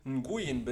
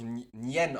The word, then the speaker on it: Nguyen: Aber wenn ich jetzt Li Fong Nguyen bin, und oder das wird ja, glaube ich, Nien ausgesprochen, wenn ich Li Fo Nien bin und mache mir China-Imbiss Shigon Dragon auf.